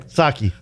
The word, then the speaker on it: Psaki: Psaki. – So, you know, in Japan, instead of a margarita. – The P is silent.